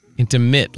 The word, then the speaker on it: intimate: Intimate, like a mitt, like you put a mitt on your hand, not mint.